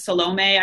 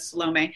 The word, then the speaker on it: Salome: known as Salome. I think more European pronunciation is Salome.